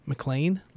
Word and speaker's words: McLean: Uh, tonight, uh, we've got a really cool interview with Miss uh, McLean Lessenberry. That's right. She, I asked her how to pronounce that, and she said just like Die Hard, and I went, oh.